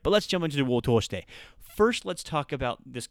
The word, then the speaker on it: を通して: But the を通して is a long o, and it's not elongated with the u sound, so if you're writing it, it's 通して, so two o's in a row, all right?